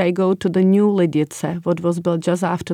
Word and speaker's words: Lidice: So we pronounce it as Lidice. – Lidice. – Lidice, because there is the ts sound.